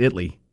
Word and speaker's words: Italy: In the midday show, he will tell you that if because there's a little town near Waxahachie called, it's spelled Italy, but according to Chad, it's Italy. Like, you can't call it Italy.